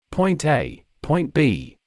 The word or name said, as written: point A point B